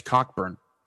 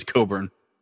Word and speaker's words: Cockburn: If you don't know who I'm talking about, you may know him as Cockburn, but it's pronounced as Coburn.